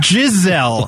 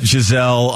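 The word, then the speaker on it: Gisele: It's Giselle. – Who is? Am I mispronouncing it? Yes, it's not Giselle. – She doesn't have horns, Gambo. – It's Giselle. She's not fast like a gazelle.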